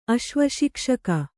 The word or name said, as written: ಅಶ್ವಶಿಕ್ಷಕ